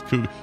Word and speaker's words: Cuoco: I say it like she said it, which is Cuoco.